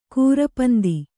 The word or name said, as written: ಕೂರಪಂದಿ